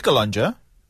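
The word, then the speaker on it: Calonge: Sí, Calonja.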